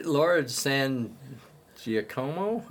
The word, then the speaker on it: Laura San Giacomo: I mean, Laura San Giacomo, I think is how you pronounce it. – Nailed it.